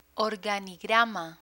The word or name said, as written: organigrama